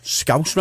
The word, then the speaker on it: Scouse: But this whole Scouse House, now, I think the reason it got labelled Scouse House is because Scouse rhymes with house.